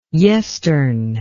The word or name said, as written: yestern